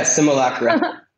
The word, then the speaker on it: simulacra: simulacra. Am I saying that right? yeah, simulacra.